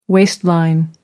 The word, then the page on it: waistline: American English